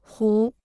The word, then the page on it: 虎: hǔ